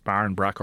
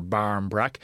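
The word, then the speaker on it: barmbrack: Somebody said, yeah, the connection, and the it's in Irish bairn brack, so it can be pronounced barn brack or barm brack.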